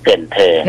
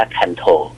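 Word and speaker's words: Cần Thơ: เกินเธอถ้าอ่านภาษาอังกฤษจะอ่านว่าคันโธ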